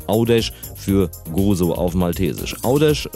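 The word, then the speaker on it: Għawdex: G-H fällt also ganz weg am Anfang. Dann kommt ein A, das bleibt auch so. Dann ein W, das sich U ausspricht. Dann haben wir Au. D und E bleiben, haben wir Au, D. Folgt zum Schluss ein X. Da haben wir gelernt, das ist ein Sch ausgesprochen. Macht aus Gavdex Audesh.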